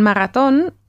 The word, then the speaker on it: Marathon: que se escribe a la antigua forma con el TH y se dice a ver cómo se dice en alemán. Maratón. Sí, con la A larga, ¿no?